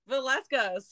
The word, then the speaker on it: Villescas: Villescas.